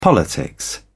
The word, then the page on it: politics: British English